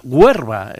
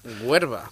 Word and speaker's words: Huerva: Que no es que esté pronunciándolo mal, sino que es que se llama Huerva, la localidad de Zaragoza. – Huerva. – Cuarte de Huerva, sí señor, con R.